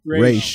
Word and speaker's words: Ra's: It's pronounced Ra's, everybody. Ra's.